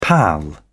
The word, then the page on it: pal up: British English